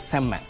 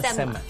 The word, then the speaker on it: Semma: It's actually the real pronunciation is Semma. Not Semma.